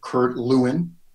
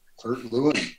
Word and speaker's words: Kurt Lewin: And I brought up Kurt Lewin, right? I pronounce it right. Kurt Lewin.